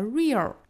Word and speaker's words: real: Real life 这个 real 大家一定要注意啊，尾部不要读成了 real，尾音呢并不是 r 的发音，注意是舌侧音 l 的发音。